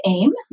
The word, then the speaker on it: AYM: So it is actually pronounced AIM, like you're aiming at a target.